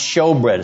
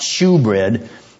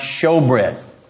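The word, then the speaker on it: shewbread: That's literally what the old King James word showbread meant, spelled S-H-E-W, but pronounced showbread. It's not shoebread, it's pronounced showbread.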